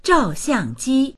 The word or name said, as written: zhao4xiang4ji1